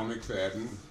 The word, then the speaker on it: McFadden: Lyle McFadden, L-Y-L-E, M-C, capital F-A-D-D-E-N.